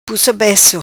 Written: Pussa Beco

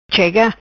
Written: Chega

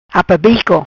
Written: Apabico

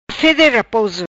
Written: fede raposo

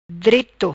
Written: Dreto

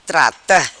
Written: Trata

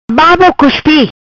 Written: Babo cuspi

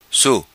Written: Su

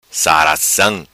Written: Saracam

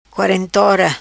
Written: Quarentora

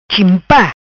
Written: Chimpa

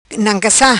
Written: Nancassa

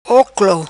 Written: Oclo

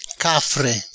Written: Cafre

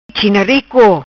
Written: China rico

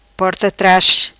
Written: Porta tras

